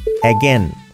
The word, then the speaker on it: again: Again. De nuevo. Otra vez. Again. Again. Cuidado. Again. Hay una A, otra A y una I. Dos A's y una I y se pronuncian dos E's.